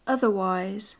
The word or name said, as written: otherwise